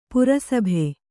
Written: ಪ್ರಸಭ

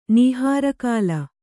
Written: ನೀಹಾರಕಾಲ

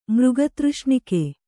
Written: ಮೃಗತೃಷ್ಣಿಕೆ

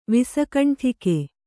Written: ವಿಸಕಂಠಿಕೆ

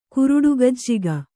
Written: ಕುರುಡುಗಜ್ಜಿಗ

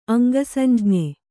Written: ಅಂಗಸಂಜ್ಞೆ

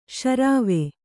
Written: ಶರ್ವ

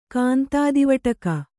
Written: ಕಾಂತಾದಿವಟಕ

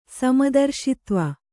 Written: ಸಮದರ್ಶಿತ್ವ

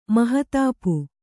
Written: ಮಹತಾಪು

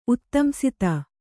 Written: ಉತ್ತಂಸಿತ

